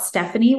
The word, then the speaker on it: Stephanie: So my name is spelled Stephanie with a P-H, S-T-E-P-H-A-N-I-E, center, C-E-N-T-E-R.